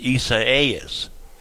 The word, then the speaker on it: Isaias: It's the Portuguese-Spanish iteration of Isaiah. Isaias.